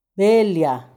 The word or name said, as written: belia